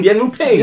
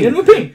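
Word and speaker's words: Yuen Woo-ping: Director, Wu Ping Nguyen. – It's Yen Wu Ping. – Yen Wu Ping.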